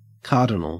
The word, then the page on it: cardinal: Australian English